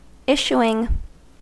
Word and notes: issuing: American English

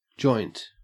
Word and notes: joint: Australian English

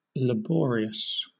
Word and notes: laborious: British English

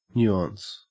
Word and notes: nuance: Australian English

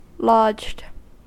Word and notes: lodged: American English

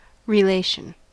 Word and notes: relation: American English

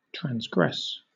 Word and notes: transgress: British English

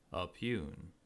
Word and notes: oppugn: American English

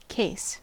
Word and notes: case: American English

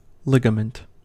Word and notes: ligament: American English

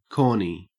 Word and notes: corny: Australian English

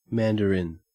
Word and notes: mandarin: Australian English

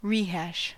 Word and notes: rehash: American English

